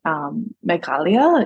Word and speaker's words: Meghalaya: where it's Meghalaya, but really as I've kind of learned, Meghalaya is the more accurate pronunciation, but we learn.